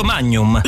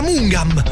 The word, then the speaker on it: Magnum: E non si è accorto che sul lato c'è scritto Magnum. – Lui mi ha detto che si leggeva Mungam.